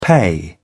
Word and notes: pay off: British English

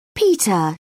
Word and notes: peter: British English

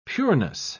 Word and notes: pureness: British English

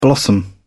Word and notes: blossom: British English

